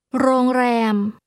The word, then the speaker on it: โรงแรม: โรงแรมแรมแรมโรงโรงแรม This is pronounced with two mid-level tones.